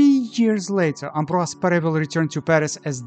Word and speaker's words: Ambroise Paré: Well, in English there are numerous ways to say his name. Ambroise Paré, Ambroise Paré, Ambroise Paré, Ambroise Paré, Ambroise Paré. But let's pronounce his name Ambroise Paré for now. For your own research, you can use the spelling AmbroisePare P-A-R-E with acute accent, Ambroise Paré.